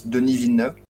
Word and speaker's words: Denis Villeneuve: And just for information, it's Denis Villeneuve.